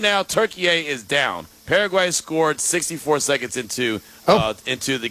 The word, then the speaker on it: Paraguay: So, I always pronounce it Paraguay. – Okay, I'll rock with Paraguay then because it doesn't rhyme with Turkey A and right.